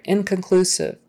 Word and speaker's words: inconclusive: So the con part is not a stressed syllable, right? Inconclusive, inconclusive. So that O is not going to be a long O. It's not going to be inconclusive. It's going to be it's just going to kind of turn into a lazy vowel, inconclusive, inconclusive. And it's usually inconclusive, not inconclusive, inconclusive. Just like infant, not infant, E-E-E, not that kind of a hard vowel, long E sound, infant, infant.